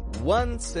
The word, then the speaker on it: once: And notice the word once. It's spelled O-N-C-E, but the pronunciation is getting this W sound.